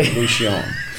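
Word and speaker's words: Revolución: Was it Avenida Revolution? – Yeah. – Okay. – Now, Revolucion. – It's going to be Revolucion, right? – Okay. – I mean, that's the correct pronunciation. – Yeah. – I think in the song, Sam does say, Avenida Revolution. But it's Revolucion.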